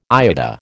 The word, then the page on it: IATA: American English